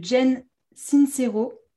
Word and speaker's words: Jen Sincero: Il y a You Are a Badass de Jen Sincero. C'est comme ça qu'on le prononce.